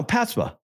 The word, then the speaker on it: PASPA: Start saying PASPA. PASPA. Keep putting a P in front of the S. You say PAPSA. – No, PASPA.